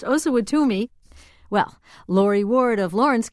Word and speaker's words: Osawatomie: The town that President Obama is speaking in is pronounced Osawatomie. This is Sue Parker in Wichita, Kansas.